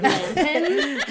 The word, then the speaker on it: Atlantans: atlanteans Atlanteans, Atlanteans. Is that how you say it? Atlanteans. – I don't think so, but we're Atlantans.